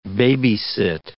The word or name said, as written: babysit